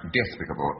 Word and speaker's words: despicable: The root is despite, therefore it's pronounced despicable. No, you don't accent the I.